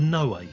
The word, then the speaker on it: Noé: Ladies and gentlemen, that was Irreversible, released in 2002, directed by, I'm saying it's Gaspar Noé, isn't it? – Noé, yeah, it's got an accent. – Got the accent on the E.